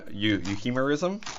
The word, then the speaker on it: euhemerism: All right, let's talk about the term that I'm surely going to mispronounce here, euhemerism.